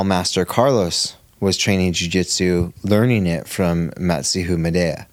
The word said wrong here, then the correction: Mitsuo Maeda: You said it so wrong, I can't remember how it's actually said. – Yes! we'll just call him Madea, right? – Mitsuo Maeda.